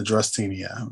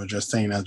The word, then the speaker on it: Dracaena: It's a Dracaena. A Dracaena, rather. No, Dracaena, Dracaena. Depends on, you can pronounce it either way.